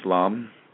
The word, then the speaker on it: lam: It's spelled L-A-M, pronounced lam.